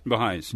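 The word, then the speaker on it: Bahá'ís: Is that how it's pronounced? Baha'is? – Baha'is. Mm-hmm.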